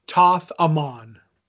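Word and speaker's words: Thoth-Amon: Toth, the way I've been told is pronounced is Toth Amon.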